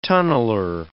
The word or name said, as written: tunneler